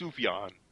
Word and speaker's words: Sufjan: It's written Sufjan, but it's pronounced Sufjan. Yeah, it's Sufjan Stevens.